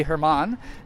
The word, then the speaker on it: Herman: I'm returning with a wonderful cosplayer and now photographer that I've interviewed before, and actually I know how to say his name correctly now. That'd be Herman. And I actually said German for the longest fucking time, like everybody else, but how have you been, sir? – I'm doing good.